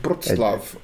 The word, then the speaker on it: Wrocław: mas o, eles chamam em polonês uh, um, Wrocław.